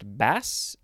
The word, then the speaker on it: baas: Now we have, I think it's pronounced bass, B-A-A-S.